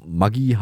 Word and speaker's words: Maggi: Wie müsste eigentlich die korrekte Aussprache der bekannten Speisewürze Maggi lauten? – Das ist nicht leicht. Das müsste eigentlich Maggi ausgesprochen werden, weil der Vater des Erfinders Julius Maggi Italiener war.